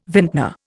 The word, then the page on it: vintner: Indian